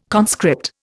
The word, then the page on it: conscript: Indian